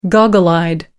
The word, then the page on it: goggle-eyed: American English